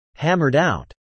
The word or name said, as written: hammered out